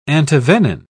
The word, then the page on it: antivenin: British English